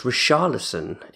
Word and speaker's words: Richarlison: I say Richarlison. I think actually it should be pronounced Richarlison, if I'm correct. – Okay, well, if you want to do a Brazilian myself. R, it's more of a her.